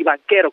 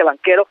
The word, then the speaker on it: Banchero: Del otro lado, del lado de Orlando, viene Paolo Banchero, así, Banchero, como se escucha. Se escribe con CH, el italiano se pronuncia Banchero.